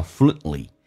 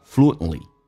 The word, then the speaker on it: fluently: OK， amiable，记住我写的是美式发音，第一个是双元 A， A-M-I 加个半元音 yeah， amiable。 More 这是 what's more, 此外呢 you must speak English fluently, 你必须要说流利的英文，这边有一个单词叫 fluently, OK, 记住 NTL 这三个辅音在一起，在规则当中中间那个辅音不用发音，叫 fluently, 同时呢 flu 跟 a 中间啊有这个是两个元音，中间可以加个半元音加个 w 叫 fluently, fluently, 气流阻塞一下 fluently, 流畅的流利的。